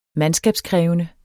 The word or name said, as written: mandskab